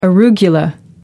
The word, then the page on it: arugula: American English